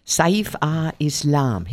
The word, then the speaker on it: Saif al-Islam: In the world this week, I saw, first of all, Muammar Gaddafi's son, Saif al-Islam.